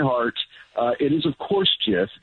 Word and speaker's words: GIF: Didn't he come out years ago and say it is GIF with the soft J?